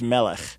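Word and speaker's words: Melech (מלך): When I did look up the word angels, if I saying it correctly, it's Melach, meaning king, like Melach Hello. – Let me help you there. Those are completely different words. The word for king is Melech.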